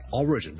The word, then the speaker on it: origin: Number two 呢，如果读成 origin 呢就会怪怪地了，因为个重音其实 origin, O-R-I-G-I-N 个来源呢，我们的重音是第一个音节的，所以 single law, origin, origin。第三件事要留意，如果读成 origin 呢，除了我将个重音放在第二个音节之外呢，我们的 vowel 都不同了，我们不是 region，譬如我 R-E-G-I-O-N 一个地区，我们的 region 呢，那呢就是 re，我们的 e vowel，但是 origin 的 re 呢其实我一个一个音，是一个松一点，没那么紧，没那么 e 的一个音。